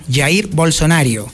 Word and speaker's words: Jair Bolsonaro: Así es como se pronuncia ya, Jair Bolsonaro.